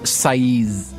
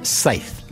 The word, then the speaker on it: Saiz: Sorry, Albert Saez. – It's a Spanish name, and it's pronounced Saez, exactly like the agricultural tool you use to mow the grass. – But it's spelled S-A-I-Z. – S-A-I-Z, yeah.